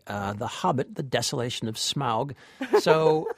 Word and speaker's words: Smaug: I always thought it was pronounced smog, like the Los Angeles pollution, but it's not. It's pronounced Smaug.